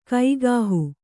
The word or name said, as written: ಕೈಗಾಹು